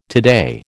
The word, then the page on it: today: American English